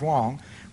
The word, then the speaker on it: Huang: A man named John Wong, is spelled H-U-A-N-G, but pronounced Wong, was given a position in the Clinton administration overseeing trade policy.